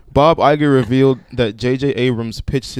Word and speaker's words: Iger: Did I say that right, Iger? – Yep.